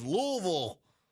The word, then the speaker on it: Louisville: Notice those of you in Kentucky, I've said it, Louisville, you got to sound like you got marbles in your mouth to correctly pronounce the city. Louisville. I cracked myself up. It's not Louisville or Louisville. It's Louisville.